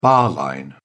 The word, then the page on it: bar line: British English